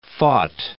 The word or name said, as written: fought